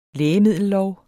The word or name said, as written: lægemiddellov